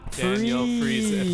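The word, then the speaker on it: Danielle Friesen: It's a long name. It's Daniel Friesen. Four syllables.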